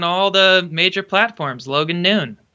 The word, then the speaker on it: Noone: Talk Mental Health with Logan Noon. Noon is spelt like no one smushed together.